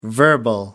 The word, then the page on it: verbal: American English